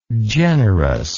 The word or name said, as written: generousness